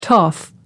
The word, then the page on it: toff: American English